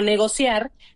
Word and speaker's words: negocia: Pero en realidad, tanto financiar como negociar no hacen hiato, se mantienen como palabras graves.